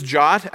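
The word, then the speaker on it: JWT: You call it JOT for short. They even put that in the spec, pronounced, quote, J-O-T, close quote.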